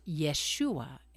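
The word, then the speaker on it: Yeshua (ישוע): In the Hebrew language, they don't use J's. And that's why Jerusalem is actually pronounced Yerushalayim. And Jesus is actually pronounced Yeshua.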